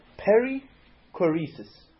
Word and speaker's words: perichoresis: The Greek word is perichoresis. Perichoresis. Peri means around or about. P-E-R-I. Again, it's a Greek word, so I'm going to spell it in English. So choresis, so like C-H-O-R-E-S-I-S.